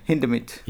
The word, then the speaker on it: Hindemith: Yeah, Hindemith.